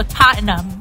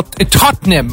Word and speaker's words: Tottenham: So it's also Tottenham. – Yes, well, Tottenham, I've heard. It's not, there's no A or E.